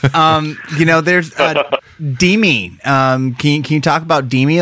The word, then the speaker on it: Dimmi: Yeah, Dimi, D-I-M-M-I, like the Italian word, Dimi.